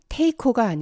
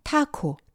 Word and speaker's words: taco: 스페인어에서 온 음식 타코는 테이코가 아니라 타코, T-A-C-O. 이탈리아 말에서 온 피자, 터키 말에서 온 소파, S-O-F-A.